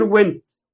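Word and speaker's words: Wendt: فراح قايل له الكسندر وينت. النطق بتاع اسمي الكسندر وينت، ولا فينت ولا فيندت ولا الكسندر وينت.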